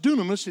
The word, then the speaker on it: dunamis: It's the word dunamis. It's pronounced dunamis. It's spelled D-Y-N-M-I-S. Where do we get? We get the word dynamite from that.